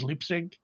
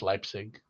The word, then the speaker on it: Leipzig: Oh, the other match is City versus Leipzig. And I say that wrong. It's Leipzig.